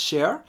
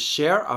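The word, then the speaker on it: cher: So Rebe says share, and it's pronounced as share or sha in Louisiana, and it's when you meet someone, it's a term of endearment.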